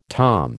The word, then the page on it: tom: American English